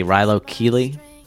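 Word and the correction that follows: Rilo Kiley: Kylie. – Is it Rilo Kylie?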